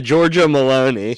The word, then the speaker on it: Giorgia Meloni: Giorgia Meloni? That's right. – Yeah, Giorgia Meloni.